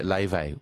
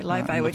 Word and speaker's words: Laufey: I'm loving that she's getting into Leve, which is a beautiful singer. Where's Leve from? – Leve. – If you're looking up Leve, I've never heard of Leve. – It's spelled Laufey.